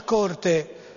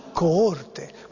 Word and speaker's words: coorte: Vabbè, la mia maestra insegnava, quando si cantava l'inno di Mameli, a dire: attenzione, non è stringiamoci a corte, faceva stringiamoci a coorte.